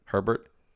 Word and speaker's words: Herbert: Herbert.